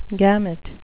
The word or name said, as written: gamut